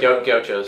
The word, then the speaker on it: Gauchos: Gauchos.